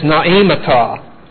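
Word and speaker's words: noemata: If you want to look the word up, that word is naimata. Naimata. N-O-E, long E, M-A-T-A.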